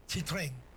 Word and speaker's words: Citroën: Citroën, no, Citroën es Citroën. No es Citroën.